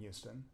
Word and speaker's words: Houston: And so growing up in Houston, as I say it, I don't know about you, but I don't actually pronounce the H. I say Houston.